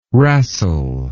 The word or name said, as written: wrastle